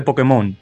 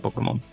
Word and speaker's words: Pokémon: Eh, soy, un niño de los 90, tengo que tener esas referencias de Pokémon. O, perdón, Pokémon, como dicen allá en, la península, que ustedes sí le pronuncian la e, nosotros vamos a los gringos.